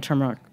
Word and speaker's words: turmeric: Like you talk about even the pronunciation, you pronounced the R, and just like the person at the cafe who was like, oh, like, I don't, they didn't even understand you, you know, like you obviously speak like perfect plain English, like you were born in this country. Mm-hmm. And just because you pronounced, I mean, just say it, turmeric. Yeah. They were like, no one had heard the R in that word before and they couldn't even understand what you were saying.